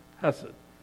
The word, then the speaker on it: hesed: And it's the word that we know as hesed, phonetically spelled H-E-S-E-D, hesed.